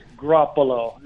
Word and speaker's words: Grappolo: Did I pronounce that correctly? – You did almost, Jack. It's Grappolo. It is the Italian word for grape bunch.